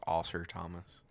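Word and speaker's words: Ausar Thompson: I didn't say anything a minute ago, but it's pronounced Oscar Thomas.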